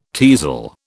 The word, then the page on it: teazel: British English